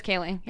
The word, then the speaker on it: Kaling: Is that how you pronounce it? I've always pronounced it Mindy Kaling. Okay. Have you heard it? – It is Kaling.